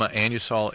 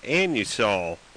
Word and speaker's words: Anusol: And by the way, Anusol, shouldn't you pronounce that anusol? – It's A-N-U-S-O-L.